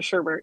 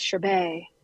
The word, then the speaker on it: sherbet: Also, like, they don't spell sherbet the way we pronounce it, right? It's sherbet. – Yeah, but here we are adding R's all over the place, like doing whatever we want.